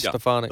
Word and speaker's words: Stefanik: Is it Stefanik? Is that the way you pronounce her name? – Elise Stefanik.